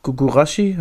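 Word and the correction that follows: Guirassy: heißt er? Girassi. ja, Girassi. ja, ja, ja, stimmt.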